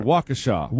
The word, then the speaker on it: Waukesha: It's called Waukesha.